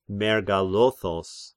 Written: Mergalothos

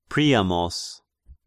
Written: Priamos

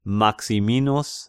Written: Maximinus

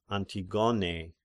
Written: Antigone

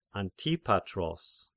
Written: Antipatros

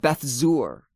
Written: Beth-Zur

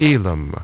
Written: Elam

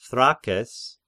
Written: Thrakes

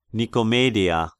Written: Nikomedeia

